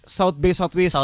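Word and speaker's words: South by Southwest: Oh iya iya South South Bay Southwest.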